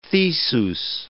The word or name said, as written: Theseus